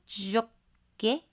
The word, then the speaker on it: Jacquet: Which is a 2005 French nature documentary directed by Luc Jacquet. Do you pronounce the T? No. – I don't. – Yes? No. – I mean, I don't ever say it. – Luc Jacquet?